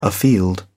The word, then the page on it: afield: English; British English